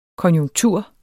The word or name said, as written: konjunktur